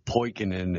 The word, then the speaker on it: Poikonen: My name's Steve Poikonen, and as Chris mentioned, I host Slow News Day and AM Wake Up.